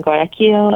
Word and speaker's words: Guayaquil: Guayaquil. I hope I said that right. – Yes, Guayaquil.